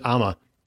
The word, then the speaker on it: OMMA: Here she wrote the sponsored content pieces that won the 2014 and 2015 Best Native Advertising Execution OMMA Award. Did I say that right, or is it AMA? – Well, either way. – Does it work both ways?